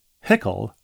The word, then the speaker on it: Hueckel: But there's a last name as well, H-U-E-C-K-E-L. The podcast was recommended by my friend Remi, like Do-Re-Mi. Hickle rhymes with pickle.